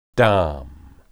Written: dam

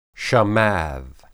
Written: shamad

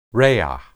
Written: rea

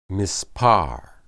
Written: mispar

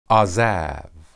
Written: azab

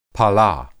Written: pala

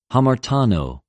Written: ἁμαρτανω

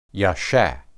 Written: yesha

